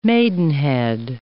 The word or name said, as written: maidenhead